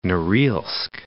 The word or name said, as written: Noril'sk